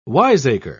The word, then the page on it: wiseacre: British English